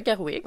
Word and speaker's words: Gerwig: Donc, Barbie, le film de Greta Gerwig, qui est sorti, j'espère que je le prononce comme il faut, Gerwig? – Ah, oh, c'est ça, Gerwig.